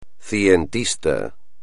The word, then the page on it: cientista: espagnol